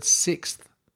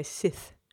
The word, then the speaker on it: sixth: And she always also mentions that the word sixth is being mispronounced a lot. I haven't detected that one. – I haven't detected that one either. What was it she said? They say Sith.